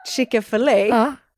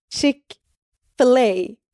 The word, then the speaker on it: Chick-fil-A: Och men vänta, varför la du A på slutet? Chick-fil-A. – Nej. Nej, för man tänker att det är Chick-fil-A, men det är Chick-fil-A. – Chick-fil-A.